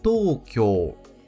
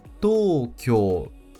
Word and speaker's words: Tokyo: A segunda leitura deste kanji é to, to, com o prolongamento da sílaba to. A primeira palavra com essa leitura é Tokyo. Tokyo.